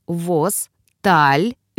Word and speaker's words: Восталвин: Восталвин произносится как Восталвин, ударение на второй слог.